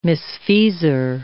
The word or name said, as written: misfeasor